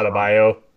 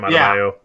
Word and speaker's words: Adebayo: Adebayo. Bam Adebayo.